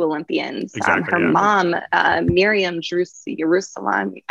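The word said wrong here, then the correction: Jerusalmi: I'm going to mispronounce her last name. – Yeah, Yerushalmi, yeah.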